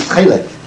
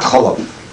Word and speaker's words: chelev: So the right pronunciation is chelev, not cholov.